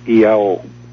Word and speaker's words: Iao: The Polynesians have a word, a sacred word, which is spelled I-A-O, Iao.